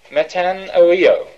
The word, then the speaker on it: metanoeo: That's it in Greek, metanoeo. I'll spell it in English, M-E-T-A, meta, N-O-E-O.